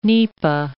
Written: nipa

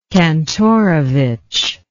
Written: kantorovich

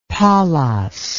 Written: polos

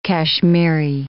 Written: Kashmiri

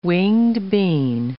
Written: winged bean

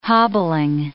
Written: hobbling

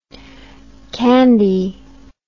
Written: candie

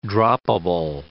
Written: droppable

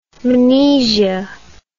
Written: mnesia

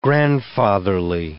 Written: grandfatherly

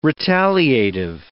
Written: retaliative

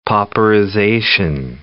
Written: pauperization

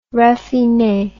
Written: raffine

